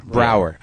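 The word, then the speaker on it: Braugher: You just have to deal with it in more, And Andre got Andre Brauger, is that how you pronounce it? – Brauer.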